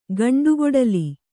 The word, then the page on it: ಗಂಡುಕೊಡಲಿ: gaṇḍu koḍali